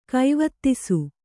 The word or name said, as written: ಗೋವತ್ಸ